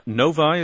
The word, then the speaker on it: Novi: Daniel is on the line with us from Novi. Is that how you say it, Michigan? – Yes.